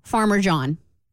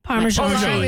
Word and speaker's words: Parmesan: My three-year-old son asked for Farmer John. – Farmer John? – Farmer John. – It's how you say it, not what it is. – Farmer John. – Farmer John. – Parmesan.